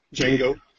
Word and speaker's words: Django: Django. Yeah. Oh, the D is silent.